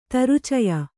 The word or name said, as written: ತರುಚಯ